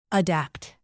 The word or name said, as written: adapt